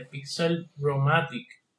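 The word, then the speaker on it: Pixlr-O-Matic: bueno, en iOS, yo recientemente estoy utilizando la de Pixel Romatic. Se escribe Pixlr Omatic, así mismo.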